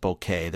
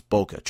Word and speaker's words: bokeh: although some people pronounce it bokeh, they're wrong. It's bokeh, trust me.